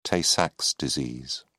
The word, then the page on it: tay-sachs disease: British English